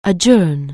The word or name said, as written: adjourn